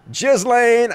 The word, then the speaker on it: Ghislaine: Ghislaine, I know that's how you pronounce it.